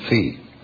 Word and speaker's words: phi: Of course, he pronounced it phi. Nice job, Stettner, Langdon said. Everyone, meet phi, P-H-I.